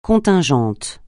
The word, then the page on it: contingent: francais